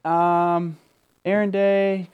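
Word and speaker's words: Aron: Aaron Day comes together for everyone named Aaron, 1A.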